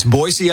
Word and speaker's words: Boise: Boise, Idaho, right? – People always say it with a Z, right? – That's right. It's Boise, Idaho.